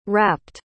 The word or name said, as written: rapped